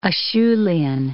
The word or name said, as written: Acheulian